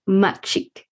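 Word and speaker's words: Mak Cik: And that's pronounced Machik, Malay for auntie.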